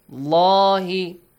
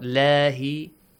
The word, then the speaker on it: الله: عند الله, the word Allah, the lam in the word Allah is heavily pronounced. عند الله, right? الله, not lahi.